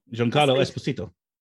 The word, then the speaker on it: Giancarlo Esposito: but I think Giancarlo Esposito, is that his name? – Giancarlo Esposito. – Yeah, him. Lovely, lovely, lovely pronunciation there.